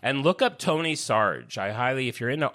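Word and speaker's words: Sarg: But they were very kind and told me that you pronounce it Sarge.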